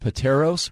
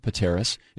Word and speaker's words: Pateros: Yeah, this is over by Pateras, which I used to think was pronounced Pateros, but it's actually pronounced Pateras.